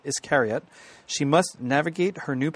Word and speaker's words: Iscariot: I'm up on page 300 for Iscariot. I think that's how you'd pronounce that. – Yep, as in Judas Iscariot.